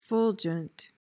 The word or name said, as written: fulgent